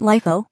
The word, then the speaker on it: LIFO: LIFO, Last In, First Out, pronounced LIFO.